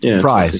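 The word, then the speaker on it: Frye: They're the parents, and you're playing one of the kids along with Francois Arnault and Chelsea Frey. Is that how you pronounce her last name? – Fry.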